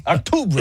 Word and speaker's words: Octubre: Octubre.